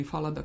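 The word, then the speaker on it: Fallada: A onda recimo vrlo su onako informativni i laki za čitanje i Hans Falada ili Falada, kako se izgovara na nemačkom,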